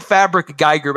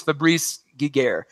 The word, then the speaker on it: Fabrice Giguère: It looks like Fabric Geiger, but I think it's Fabrice Giguère.